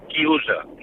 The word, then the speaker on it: chiusa: Y un momento, y quiero decirle a Fernando que en italiano se dice chiusa. Coro a boca chiusa.